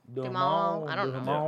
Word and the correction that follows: Duhamel: It's Duhamel. Duhamel.